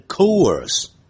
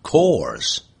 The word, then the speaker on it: course: for 课程不注意可能会念 course，实际上要念成 course， course。